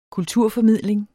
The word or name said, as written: Kulturformidling